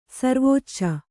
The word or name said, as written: ಸಿರಿವಚ್ಚ